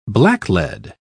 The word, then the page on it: blacklead: British English